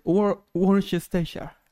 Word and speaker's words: Worcestershire: Sería como Worcestershire. – Es Worcestershire. Ajá.